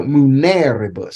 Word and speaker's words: muneribus: It's funny that you know munera, you pronounce the first syllable, but on the next word, you have to pronounce the third from the end because it's too long, you know, muneribus.